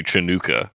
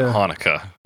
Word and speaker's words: Hanukkah: And he said Happy Chinookah. – Oh, because that's like an alternate spelling. – Yeah, with the C. – But you, like, I don't know how you're supposed to pronounce it, but it's not a C-H.